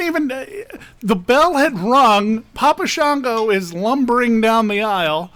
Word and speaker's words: Papa Shango: Because of the Papa Jungo, right? That's how you say it? – Papa Shango Jungo.